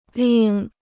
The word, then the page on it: 另: lìng